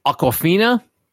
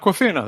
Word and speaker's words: Awkwafina: Awkwafina. Tell me I'm saying that right. – Yeah, yep. Awkwafina. That's her name. – Tell me I'm saying it exactly like the bottled water.